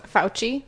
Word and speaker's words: Fauci: So Dr. Fauci, that's how you say his name, right? – Fauci. Mm-hmm.